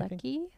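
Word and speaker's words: Lecce: Leachy, I think. – Leachy? I Lecky? – Sometimes oh, I Lecky. call him Leach. Yeah, Lecky. Yeah.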